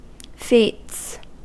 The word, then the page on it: feats: American English